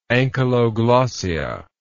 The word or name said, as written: ankyloglossia